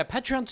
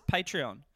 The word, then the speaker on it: Patreon: Now, I pronounce it Patreon. You call it Patreon. Oh. I don't know what it is, but I figure Patreon sounds a bit more like patron.